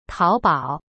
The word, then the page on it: 淘宝: tao bao